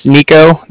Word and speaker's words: Niko: So like I said, my name's Nico. That's spelled N-I-K-O.